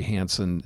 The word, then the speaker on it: Hansen: My name is spelled K-A-I-L-A and then White. – And you can follow me at Ronald J. Hansen, and that's H-A-N-S-E-N.